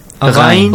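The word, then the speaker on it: غين: And Ghain. Ghain, not Ain. Ghain, the brother.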